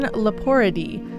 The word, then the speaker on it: Leporidae: And up next, we have Brianna Fenty's submission, Leporidae. – Yep, you got it right. That's how it's pronounced.